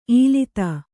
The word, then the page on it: ಈಲಿತ: īlita